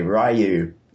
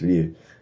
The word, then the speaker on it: Ryu: it's the same as when Street Fighter 2 was big, and then I was like, I'm going to play Ryu. And then, like, you find out years later it's pronounced Ryu.